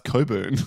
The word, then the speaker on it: Cockburn: There is a place called Cockburn. That's Coburn.